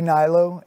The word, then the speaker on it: Hnilo: Yeah, it's at Andy Hnilo, A-N-D-Y-H-N-I-L-O. – I was like, how do you say that last name? – Oh, man, yeah, everybody thinks it's Hawaiian. – I love your last name, though, Hnilo. H-N-I-L-O.